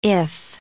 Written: if

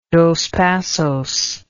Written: dospassos

